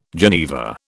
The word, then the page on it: geneva: British English